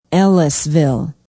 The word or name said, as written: ellisville